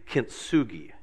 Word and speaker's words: kintsugi: But it's called, I believe, kintsugi. K-I-N-T-S-U-G-I.